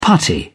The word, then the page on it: putty: British English